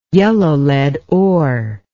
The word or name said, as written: yellow lead ore